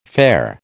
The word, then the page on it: fare: American English